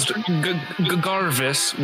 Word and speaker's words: Garvis: We have my good guy, Jarvis here. We see a sort of Garvis with a hard G.